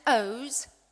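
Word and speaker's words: Oğuz: His name was Oz, but you spell it O-G-U-Z.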